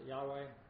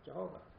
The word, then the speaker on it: Jehovah: Jehovah, some pronounce it Yahweh. Scholars cannot be sure whether it's a two-syllable word or a three-syllable. I prefer traditional three-syllable. If it is a three-syllable word, it would come out something like Jehovah, which you'll find arguments against, but I still prefer it.